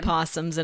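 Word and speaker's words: opossum: Well, first of all, we'll start with possums. The O is silent and feels very unnecessary, but that's just how some English words are, honestly.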